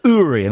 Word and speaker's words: Uri: I love Uri Geller, and it's pronounced Uri, and it makes me very uncomfortable saying Uri in the same way it makes me uncomfortable to say Moog and not Moog, but that's how they're pronounced.